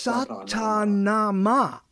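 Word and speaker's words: Sa Ta Na Ma: Satana Anna? Did I say it right? – What are you talking about? – That thing you did that you're Satana Anna? – Is it? Oh, Satanama. – Nama? – Satanama. – Yes, Satanama.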